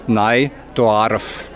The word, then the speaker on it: Neudorf: Schon wie wir den Namen Neudorf aussprechen, hört man es. Wir sagen nämlich Neidorf, von O auf OA.